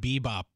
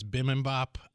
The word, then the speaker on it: bibimbap: I always call it bebop, but it's bibimbap.